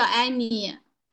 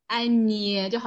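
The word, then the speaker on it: ñ: 它的发音叫埃尼，埃尼就好像捏什么东西，埃尼。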